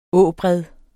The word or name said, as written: åbred